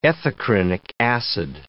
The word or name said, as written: ethacrynic acid